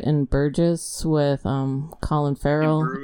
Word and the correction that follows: In Bruges: Ian Bruges.